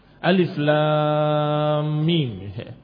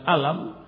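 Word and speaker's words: Alif, lam, mim (الم): Alif, lam, mim. Dibaca bukan dengan alam, tetapi dibaca alif, lam dibaca lam, dan mim dibaca mim. Demikian cara membacanya.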